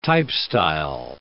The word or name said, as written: typestyle